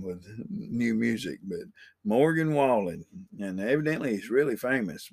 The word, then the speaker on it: Wallen: Wallen. I kept saying his name wrong for a while. I was like, Waller? Walker, maybe?